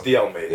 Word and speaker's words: De Almeida: Fabio, how do I pronounce your second name? How do I pronounce it? Because I'm making mistakes. Almeida, yeah. But is it Almeida? – It's De Almeida.